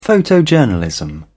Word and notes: photojournalism: British English

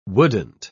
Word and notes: wouldn't: British English

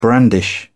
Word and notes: brandish: British English

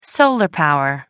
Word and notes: solar power: American English

